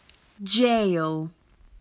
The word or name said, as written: Jail